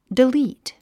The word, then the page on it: delete: dilíːt; デリート